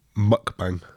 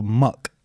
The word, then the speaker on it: mukbang: But you're saying mukbang, as in MC bang. – Me? – How's it pronounced? – Mukbang. – Oh, muck. – Like the Pokemon.